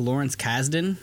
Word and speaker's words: Lawrence Kasdan: Pronounce the man's name right for me. – It's like Lawrence Kasdan.